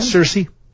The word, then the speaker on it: Saoirse: How do you say it again? – Saoirse. – Saoirse Ronan. Okay.